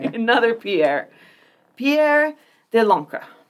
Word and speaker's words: de Lancre: Oh, I didn't pronounce that right. De Lancre. I hate when it has to end with the guttural, but I listened to like 5 pronunciations of it, and they all had that.